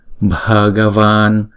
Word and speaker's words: Bhagavan: Puraya, puraya, Bhagavan, Bhagavan. Chữ này chúng ta đọc là van, chữ a kéo dài hai nhịp cho nên chữ này là van. Bhagavan, Bhagavan. Saravashan, Saravashan.